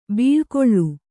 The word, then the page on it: ಬಳೆಗಳೆ: baḷegaḷe